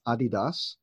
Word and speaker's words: Adidas: And so Kai is actually the director of voice and consumer analytics at Adidas, or as properly pronounced, Adidas.